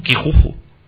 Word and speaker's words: Kijuju: Kijuju.